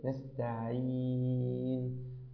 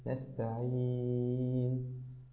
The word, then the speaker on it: نستعين: كذلك كلمة نستعين، حاول أن ترقق الياء أفضل من ذلك. نستعين نستعين هكذا يا أخي الحبيب.